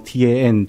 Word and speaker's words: Tan: 可是是用 TAN 做他的姓的，是胆这个字哈，陈的台语闽南语翻译发音，陈洪博牧师哈，一个非常好的一位传道人，他的有一些著作也有很多可以贡献给教会的。